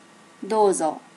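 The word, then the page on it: dozo: native Japanese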